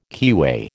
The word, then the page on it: keyway: American English